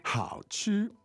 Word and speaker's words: 好吃: Well, you simply say 好吃。好吃。 – 好吃。